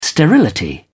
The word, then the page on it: sterility: British English